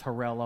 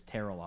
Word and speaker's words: Terrell: So his name is not Terrell Owens; it's Terrell Owens.